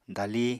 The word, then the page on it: dali: apatani